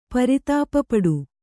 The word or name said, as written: ಪರಿತಾಪಪಡು